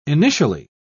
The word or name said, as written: initially